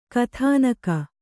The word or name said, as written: ಕಥಾನಕ